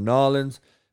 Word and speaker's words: Nollins: You know, LSU's basically playing a fucking home game in New Orleans and or Nollins. And if you're from the boot, that's how you say it, I guess.